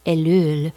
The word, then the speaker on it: Ellul: I've been reading from a book from the 1960s by Jacques, let me see if I can pronounce this the French way, Ellul, E-L-L-U-L, Jacques, I call him Jacques Ellul, The Technical Society.